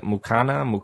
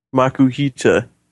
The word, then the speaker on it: Makuhita: Brawly has two Pokemon, a Machop at level 14 and a, what is it, Mukata? – Makuhita.